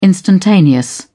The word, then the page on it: instantaneous: British English